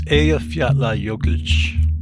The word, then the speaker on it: Eyjafjallajökull: And I'm not going to get this name right, but it's Eyjafjallajökull. – Am I pronouncing that correctly? – Nearly. – It's Eyjafjallajökull. There you go.